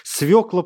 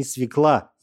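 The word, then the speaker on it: свёкла: Свекла плакать начала, до корней намокла. Я, ребята, не свекла, я, ребята, свекла.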